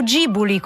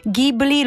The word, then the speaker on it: Ghibli: È una canzone molto piena d'amore, così come pieni d'amore sono i miei occhi quando io guardo i film dello studio Ghibli. Non so, o Ghibli come dicono i giapponesi, se volete proprio pronunciarlo alla giapponese, Ghibli loro lo leggono Ghibli.